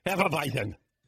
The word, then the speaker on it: Hefeweizen: Maybe a little of the Hefeweizen, as I like to occasionally, yeah. I think I said that correctly. – Hefeweizen.